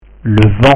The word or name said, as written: le vent